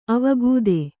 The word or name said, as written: ಅವಗತಿ